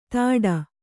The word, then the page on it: ತಾಡ: tāḍa